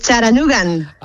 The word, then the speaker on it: Chattanooga: Es Charanugan. – Ah, Charanugan, vale, vale, vale. ¿Y el nombre de la ciudad cómo se pronuncia bien, bien? – Se dice Charanuga, con la T como muy suave, sí.